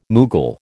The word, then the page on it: Mughal: American English